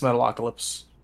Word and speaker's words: Metalocalypse: Metalocalypse, am I saying that right? – Yes, Metalocalypse.